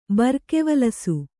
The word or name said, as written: ಬರ್ಕೆವಲಸು